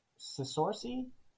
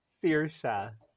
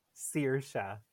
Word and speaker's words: Saoirse: If I'm just going off of how it's pronounced, it looks like Sisorsi, but I doubt that's even close to it. So I'm going to instead say it's Emily. – Emily was closer than Sorcy. Yeah. This name is pronounced Saoirse. Saoirse. We got a famous actress called Saoirse Ronan, who's actually very well known for an Irish actress in the US.